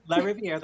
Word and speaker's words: LaRiviere: Yep, LaRiviere.